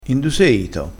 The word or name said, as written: ito